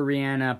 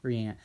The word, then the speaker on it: Rihanna: Eastern Time, 2 days before Rihanna performs at the Super Bowl Halftime Bowl. – I do know that you did just pronounce Rihanna wrong, though.